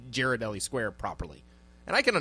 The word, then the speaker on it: Ghirardelli: Ghirardelli. – Ghirardelli, and you know how to pronounce that. Congratulations.